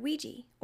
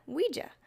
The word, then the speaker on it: Ouija: So, today's pronunciation hang-up, how do you pronounce the word Ouija or Ouija? I have always said Ouija, but if you go by what the words are said to be when broken down, you get oui, the French word for yes, and ja, the German word for yes.